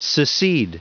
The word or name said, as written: secede